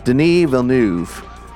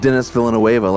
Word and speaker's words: Denis Villeneuve: So it's Denis Villeneuve. – Yep. – Not Dennis Villanueva, like I've always pronounced it.